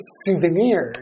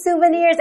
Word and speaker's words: souvenir: It looks like souvenirs. That's right. It has a funny spelling, Comes but French. – That's right, but it's pronounced souvenir.